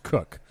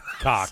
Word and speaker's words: Kuck: And his name is spelled K-U-C-K, and it's pronounced Cook. But somehow I spelled it did you do? – K-O-C-K. – Cock.